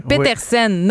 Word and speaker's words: Pettersen: Donc, c'est Petersen, Norvège. – c'est ça. Mais là, le truc, c'est de faire comme Peter Svoboda, Peter, et Sen comme un sou.